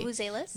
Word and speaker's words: vuvuzelas: So, the grandstand next to the race course is filled with cheering fairgoers, ringing bells, swinging rattles, you know, those vizulas. I can never pronounce them properly. – Vuvuzelas? – That's the one.